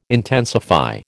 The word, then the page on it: intensify: American English